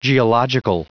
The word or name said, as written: geological